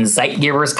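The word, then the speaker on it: zeitgeber: And zeitgebers kind of rhymes with the word light givers, and that's what it is.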